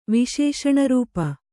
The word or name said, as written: ವಿಶೇಷಣರೂಪ